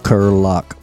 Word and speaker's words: Kiriluk: Kiriluk.